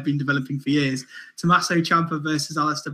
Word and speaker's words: Ciampa: Just to clarify for all you ignorant podcasters, journalists and fans out there trying to hop on the bandwagon, proper pronunciation, Ciampa is Ciampa.